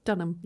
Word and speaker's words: Dunham: I remember Lena Dunham, if I said that right? – Dunham, yeah.